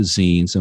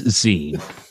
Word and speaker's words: zine: zines and what they are and how they're represented is the proper pronunciation is zine. And if anyone says otherwise, you know, I mean, it's not zine.